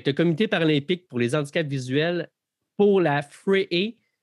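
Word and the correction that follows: Vrije: ou FREE, e, je m'excuse, Université d'Amsterdam, est-ce que je le prononce correctement? – C'est un bel effort, euh, on dit frère, euh, euh, d'Amsterdam.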